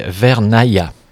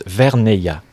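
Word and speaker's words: Vernayaz: Et si oui, pourquoi ?» Alors, le nom de cette localité devrait se prononcer Vernaya. Mais il semble effectivement que la plupart des personnes prononcent Verneia.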